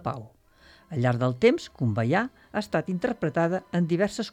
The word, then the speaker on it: Kumbaya: Kumbaya vol dir vine aquí. Els negres, en comptes de dir come by here, pronuncien Kumbaya, vol dir vine cap aquí, senyor.